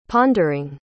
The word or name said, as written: pondering